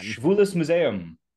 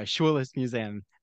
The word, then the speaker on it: Schwules Museum: And he also is a board of director for the, how do you pronounce the museum, Ben? – Schwules Museum. – There you go, Schwules Museum.